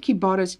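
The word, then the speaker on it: Qbara: So if you ever go to Dubai, go and eat at Kibara's, Q-B-A-R-A. If you spell it back, if you pronounce it back, it's Arabic, A-R-A-B-Q, you know.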